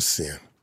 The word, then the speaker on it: Cyn: Is it S-I-N or C-Y-N? – It's spelled C-Y-N, but it's pronounced S-I-N.